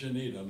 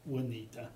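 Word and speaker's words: Jennita: Yeah, it's Janita, not, what do you call her? – Juanita. – Yeah, Janita. – Oh, sorry, it's J-E-N-N-I-T-A. Sorry, I mispronounced that.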